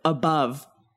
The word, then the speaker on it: above: An adjective, above. You know, same thing.